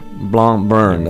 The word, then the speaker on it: Blancmange: I guess it's, you don't pronounce a C here. It would be Blancmange, right? – Blancmange. – That's Blancmange. Right.